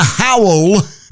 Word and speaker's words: Howell: And well done to Anna Howell, whose surname rhymes with towel.